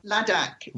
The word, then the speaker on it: Ladakh: Ladakh.